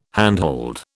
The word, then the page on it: handhold: British English